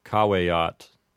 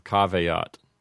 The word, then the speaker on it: caveat: So they might have said caveat instead of caveat.